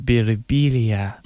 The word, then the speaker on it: Birbiglia: I looked at it at first, I thought it might be the silent G. – Yeah, no, Birbiglia. I mean, I get that a lot. Sometimes people come up to me, they'll be like, in Italy, it's pronounced Birbiglia. Mm. I'm like, in America, you're annoying.